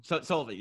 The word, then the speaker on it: Solvang: I've heard of Slovang. – Solvang, yeah. – I'm pronouncing that way wrong. – I was like, Slovenia? I'm butchering that completely.